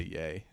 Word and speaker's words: Ye: Ye, yay. Is that how you say it? Yeah, you got to say yay.